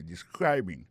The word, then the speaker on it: describing: Sa nou ka wè la, sa nou constate la, nou ka constate que li a prononcé mot de describing. Accent tombe sou deuxième syllabe la. Ça veut dire mot sa gen trois syllabes describing.